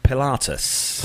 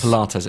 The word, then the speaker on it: Pilatus: Pilatus. – Pilates. Is it Pilates? No. No, it's Pilatus. Pilatus.